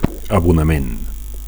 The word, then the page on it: abonament: Catalan